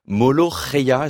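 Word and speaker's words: Mouloukhiya: Molo Reya, est-ce que je prononce bien ? – Absolument.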